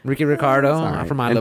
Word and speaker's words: Ricardo: Ricardo. If you're going to say in Spanish, you got to roll the R's.